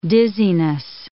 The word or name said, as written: dizziness